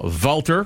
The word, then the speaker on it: Walther: If that is the case, that's going to be like an old small either Walther or Walther, as some blacks to call them, or Beretta. – Are you supposed to pronounce it with a V? – If you're doing the German thing.